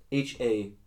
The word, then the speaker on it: Haussmann: Haussmann. – Spelled Haussmann. Haussmann. But by my pronunciation, you should at least know that he's French.